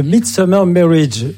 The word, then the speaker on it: The Midsummer Marriage: Alors, je voudrais juste corriger quelque chose. Oui. C'est The Midsummer Marriage. J'ai mal prononcé tout à l'heure.